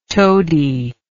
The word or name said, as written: tody